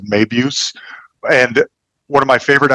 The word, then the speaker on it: Mabuse: I mean, I don't speak German, but I think it's pronounced Mabuse.